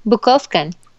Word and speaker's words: Bukovcan: Bukovkan.